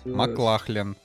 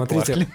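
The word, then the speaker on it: MacLachlan: Маклахлин, Маклахлин, Ч, там CH, это, это не, не читается как К.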